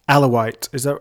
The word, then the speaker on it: Alawite: And when you speak of tribe, for people that haven't read the book, you're specifically speaking of Alawite.